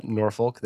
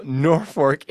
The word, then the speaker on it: Norfolk: It's not Norfolk. That's in Virginia, I think. – Well, it's spelled the same way. – Well, that's even more confusing. – You pronounce it Norfolk. – Okay. Well, Norfolk.